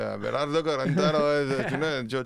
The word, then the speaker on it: Abelardo Carbonó: Tu valides, cette prononciation Ouais, Abelardo Carbono.